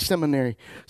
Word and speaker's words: seminary: I said it wrong for so many years, I forgot how to say it right. Seminary. I can sit on my computer and take seminary classes. I've called it cemetery for so long.